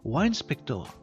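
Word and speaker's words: WineSpector: Wine, comme le vin. Donc wine, wine, wine, wine spector.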